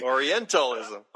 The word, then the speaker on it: Orientalism: And the stress is on the ta. – Golly. – Orientalism.